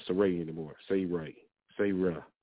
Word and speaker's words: Sarah: Sarah. It ain't even Sarah. It's Sarah. They got the emphasis. Stretch that A.